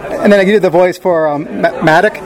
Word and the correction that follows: MODOK: MODOK, which is a Marvel series.